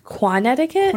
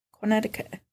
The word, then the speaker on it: Quonetiquet: Quonetiquet? – Quonetiquet. Quonetiquet. That's how it's spelled, Q-U.